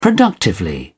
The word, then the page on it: productively: British English